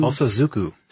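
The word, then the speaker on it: Bōsōzoku: how do you say it again? Bosozoku? – Bosozoku. – Thank you. Sorry, guys. It's the Aichi, A-I-C-H-I prefecture, also followed by Tokyo, Osaka.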